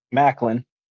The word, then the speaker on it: Maclin: All right, so TNA kicked off with Mike Bailey with Trent Seven versus Steve McClain, right? Is that how you pronounce it? – Maclin.